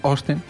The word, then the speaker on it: Austin: Permíteme que lo pronuncie bien. Austin. – Con O, ¿no? La A, uy, eso. – Bueno, la A no la dicen. Me fijé Austin. Y no lo decía, decían Austin.